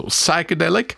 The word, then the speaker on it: Psychedelic: Bei Wörtern, die mit PS beginnen, wird das P nicht ausgesprochen, sondern nur ein scharfes S die Musikrichtung heißt also Psychedelic und der Song von den Talking Heads heißt Psycho Killer.